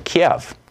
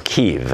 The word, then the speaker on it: Kyiv: Kiev. Or Kyiv, as apparently they pronounce it in Ukraine.